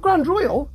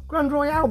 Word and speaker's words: Grande Royale: Not Grand Royal, Grande Royale. There's an E on there, so you have to pronounce it that way. Grande Royale, because there is an E on the Grand as well. Grande Royale.